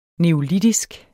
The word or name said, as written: neolitisk